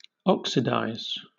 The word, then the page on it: oxidize: British English